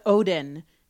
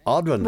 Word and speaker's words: Audrun: I'm going with Odin because it's like the god, right? – Oh, yeah, the Norse god. – Jennifer, 100%. Audrun. – What? – Yeah, that's exactly how you say it.